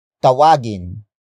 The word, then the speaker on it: tawagin: Word for word it's tawagin mo ang pusa. Tawagin mo ang pusa.